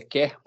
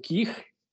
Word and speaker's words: queer: O Q é de quer, que aí eu não sei se é quer ou queer, qual é a pronúncia? Queer. Queer, né? Obrigado.